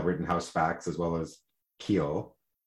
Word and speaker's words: Khill: Am I pronouncing it right? It is just K-H-I-L-L, Keel. – I think that counsel pronounced it Keel, but Keel? Okay. – The media seems pronouncing it as Keel, so.